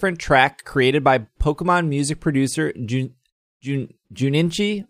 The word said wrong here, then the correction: Junichi: Wait, Ju, Juninchi? – Did I say that right? Junichi. – Junichi. Jun, There's Junichi. no extra N in there.